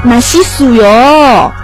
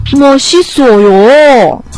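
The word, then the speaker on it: 马 西 索 哟 (맛있어요): 马西苏哟”，然后就发现哦，发音发错了呢，应该是“ 马西索哟”，然后那个帅哥说。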